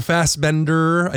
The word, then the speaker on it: Fassbender: James McAvoy and Michael Fassbender.